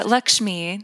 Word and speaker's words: Lakshmi: I said, I think I brought India into my field because of Lakshmi. Is that how you say it? – Lakshmi.